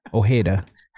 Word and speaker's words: Ojeda: He even mispronounces it more Hispanic than it actually sounds. Ojeda. – John reads the O. He thinks it's O apostrophe Hida.